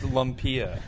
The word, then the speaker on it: lumpia: It's pronounced lumpia. – I know that. – Lumpia. – Lumpia. – Lumpia. Oh, my god!